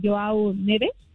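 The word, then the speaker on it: João Neves: Pero más se desató por las declaraciones que dio precisamente Joao Neves, si lo pronuncié bien.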